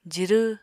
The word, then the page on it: jirw: apatani